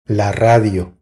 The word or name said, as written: La radio